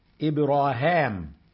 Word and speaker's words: إبراهام: عشان كده هتنطق إبراهيم في بعض القراءات إبراهام، وده النطق العبري لإبراهيم اسمه إبراهام، وحتى بالإنجليزي وبتاع يعني هو بيقروا إبراهام، تحديدا قراءة ابن عامر بالراويين بتوعه، وإن كان راوي منهم عنده الطريقتين.